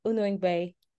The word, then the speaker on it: Unuigbe: All right, it's pronounced as Unuigbe.